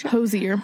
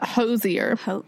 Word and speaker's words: Hozier: Hozier. It's supposed to be like cozier, but Hozier.